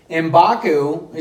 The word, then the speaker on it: M'Baku: M'Baku, is that how you say his name? – Yeah.